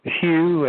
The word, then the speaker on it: Hugh: Yes, it would be my name, which is Hugh, H-U-G-H, the last name Trawlson is spelled T like Tom, R-A-U-L-S-E-N like Nancy, after my name.